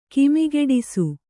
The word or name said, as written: ಕಿಮಿಗೆಡಿಸು